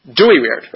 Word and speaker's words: Dooyeweerd: So number of followers of Kuyper, the most famous of the followers is a Dutch philosopher and legal theorist named Herman Dooyeweerd. I may be pronouncing his name wrong, but how do you pronounce it? Dooyeweerd.